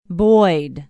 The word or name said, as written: boyd